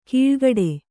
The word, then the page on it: ಕೀಳ್ಗಡೆ: kīḷgaḍe